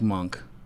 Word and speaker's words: Monch: I like monk. – That's a good one, yeah. – M-O-N-C-H. – Yeah. – It's pronounced monk.